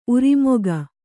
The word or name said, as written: ಊರ್ಮಿಕೆ